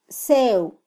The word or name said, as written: sew